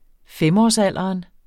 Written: femårsalderen

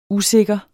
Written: usikker

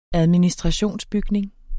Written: administrationsbygning

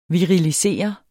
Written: virilisere

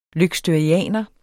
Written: løgstørianer

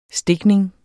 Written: stikning

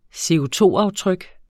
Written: CO₂-aftryk

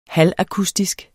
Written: halvakustisk

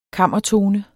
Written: KAMMERTONEN

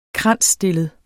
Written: kransstillet

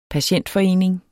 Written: patientforening

